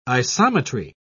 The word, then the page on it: isometry: British English